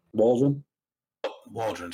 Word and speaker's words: Waldron: So the Michael Warden, is that his name? Did I say it right? – Waldron, sorry.